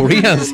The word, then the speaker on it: Brian: ¿Estamos pronunciando bien tu nombre? Porque aquí en Colombia decimos Brian. – Sí, no, lo están pronunciando muy bien. – Ok, porque de pronto es sí. Brian o algo así, no sé, aquí decimos Brian. – O Brian. Me encanta que sea así, así mismo como lo están pronunciando es la manera correcta.